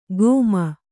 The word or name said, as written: ಗೋಮ